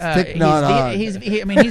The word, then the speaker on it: Thich Nhat Hanh: I don't even say his name right. It's Thich Nhat Hanh.